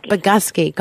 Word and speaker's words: Bogusky: and I know I never said it's Bogusky. – Bogusky. – God, I don't want to say that wrong. Yes. I'm so sorry. Bogusky. God, just doesn't sound natural for me to say it like that.